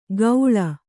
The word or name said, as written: ಗೌಳ